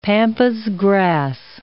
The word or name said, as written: pampas grass